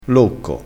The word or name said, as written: locco